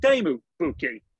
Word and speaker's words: Teemu Pukki: To my understanding, your name is pronounced Teemu Pukki.